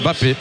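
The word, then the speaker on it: Mbappé: On ne prononce pas le M hein, chez les joueurs africains. Bamba. Enfin, le M du début du nom, je dirais. Donc la sortie de Congrève, comme on dit par exemple, Bappé.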